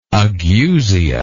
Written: ageusic

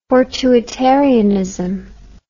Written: fortuitarianism

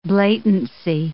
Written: blatancy

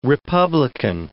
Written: republican

